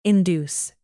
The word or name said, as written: induce